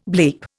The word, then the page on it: bleep: Indian